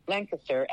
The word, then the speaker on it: Lancaster: Lancaster, actually, if you're from Lancaster, that's how you pronounce it.